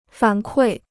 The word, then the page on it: 反馈: fǎn kuì